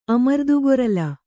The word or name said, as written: ಅಮರ್ದುಗೊರಲ್